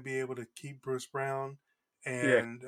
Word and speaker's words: Braun: They traded Christian Brown, B-R-A-U-N, pronounced Brown.